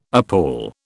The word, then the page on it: appal: British English